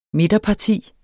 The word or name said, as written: midterparti